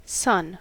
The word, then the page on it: son: American English